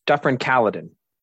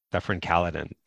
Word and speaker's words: Dufferin-Caledon: And you're currently the member of, I want to make sure I pronounce it properly, Dufferin-Caledon? – Yes, Dufferin-Caledon. – Dufferin-Caledon.